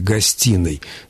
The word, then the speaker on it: гостиной: Но составители орфоэпического словаря русского языка указывают специально при слове «гостиной», что следует его произносить, не удлиняя звук «н», потому что пишут неправильно «гостиной», где вот в скобочках показано именно вот это удлиненное «н».